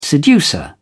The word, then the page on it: seducer: British English